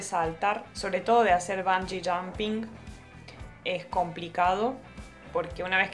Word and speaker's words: bungee jumping: Bungee jumping. ¿Lo dije bien? Lo dijiste bien, sí.